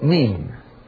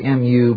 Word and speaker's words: mu: Mean. Strangely enough, spelled usually M-U, but more correct pronunciation, me, not mu. Mim, me, mween. M-I-M, Semitic, M-U, Greek, M-U-I-N, Irish. Mim, me, mean. Again, N-U-N, Semitic, N-U, nobody can really tell you the ancient pronunciation.